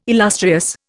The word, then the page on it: illustrious: Indian